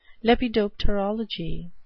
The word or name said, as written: lepidopterology